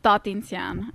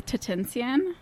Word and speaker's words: Tatintsyan: I guess it's Tatintsyan, actually, in Armenian. – Okay. – But I feel like I've Americanized it, and I say Tatintsyan. – Tatintsyan. – Yeah, it's a little, I kind of guess I removed a syllable from it.